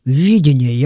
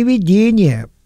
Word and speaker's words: видение: Хочу сразу оговориться, потому что вы часто будете встречать и в книге пророка Исаии, и в других книгах Ветхого Завета такое понятие, как видение. Я хочу подчеркнуть ударение на первом слоге. Не видение, потому что тогда можно было бы,